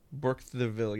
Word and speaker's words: Burkittsville: It's, I don't know how to pronounce the name, but I think it's Burkittsville, Maryland. Burkittsville, yes. – Fuck. Burkittsville? – Yes.